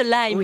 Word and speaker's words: Lyme: Ça s'écrit L-Y-M-E. – Et ça se prononce Lyme. – Ah, c'est Lyme. – Ah, pour le jeu de mots, c'est foutu. – Ah, pour une fois, j'ai prononcé à la française.